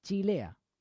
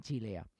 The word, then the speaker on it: Cilea: Chilea, o sea, Francesco Cilea, todo con ces, pero ellos dicen Chilea.